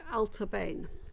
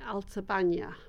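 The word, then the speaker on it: Allt-a-Bhainne: And this is called, well, the easy way to pronounce this is Altabain. But the Gaelic way to pronounce it would be Altabania. But I'm not a Gaelic speaker.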